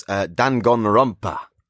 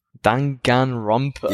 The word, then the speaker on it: Danganronpa: I'm going to butcher the pronunciation, but it's Danganronpa. – Danganronpa.